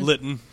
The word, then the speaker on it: Litten: Litten. – Litten. – Oh, because it rhymes with kitten.